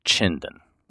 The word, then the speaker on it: Chinden: So, yep, I, I'm making the official proclamation that it should be pronounced Chindon.